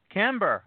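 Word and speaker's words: Cambuur: FC Zwolle, SC Camber. I think I pronounced that correctly. Hang on 1 second. Let me take a quick look here. SC Camber. I was correct.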